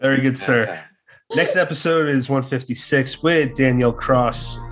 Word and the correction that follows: Kraus: Cross? I said it wrong the first time. Kraus. Did I say it right anytime? – Kraus.